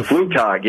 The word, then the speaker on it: Flugtag: That's a Flugtag, yeah. – A Flugtag. Excuse, I didn't know the German pronunciation of this.